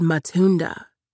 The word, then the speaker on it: Matunda: It's called Matunda, she says, putting an accent on the second syllable.